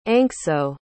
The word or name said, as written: anxo